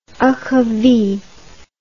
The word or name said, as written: achyfi